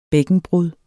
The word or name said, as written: bækkenbrud